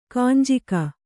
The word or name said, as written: ಕೆಂಜಗ